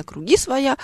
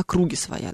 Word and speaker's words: круги своя: Недавно тут видела в сообщениях, кому-то из коллег прислали, что вот, типа вы все время делаете неправильное ударение, правильно говорить не на круги своя, э, а на круги своя.